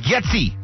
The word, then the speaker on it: Getsy: Luke Getsy. – Getsy. Spell that. – G-E-T-S-Y.